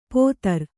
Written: ಪೊದರ್

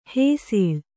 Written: ಹಸಲು

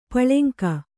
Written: ಪುಲ್ಲಿಂಗ

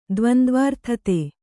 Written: ದ್ವಂದ್ವಾರ್ಥತೆ

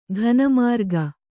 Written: ಘನಮಾರ್ಗ

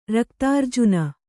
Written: ರಕ್ತಾರ್ಜುನ

